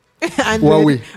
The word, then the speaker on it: Huawei: So the internet using, I don't think it's hard, because even OPE, I think OPE gives its riders, I don't know how to pronounce it, who are we? – Huawei. – Yeah. Huawei 100 phones.